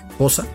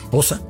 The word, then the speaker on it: Xhosa: La melodía Nkosi Sikelele Africa es una melodía de idioma Xhosa, o para pronunciarlo bien, Xhosa. Sí, lo escuchó bien, Xhosa.